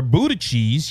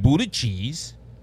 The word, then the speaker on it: Buttigieg: It's even come to a point where Buttigieg, yes, I said it right, Buttigieg,